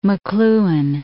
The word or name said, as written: McLuhan